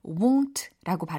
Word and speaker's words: won't: will not의 줄임 표현 발음에 되게 유의하셔야 되는 어, 단어가 나왔는데 won't라고 발음이 됐잖아요. won't look down 내려다보지 않을 거예요.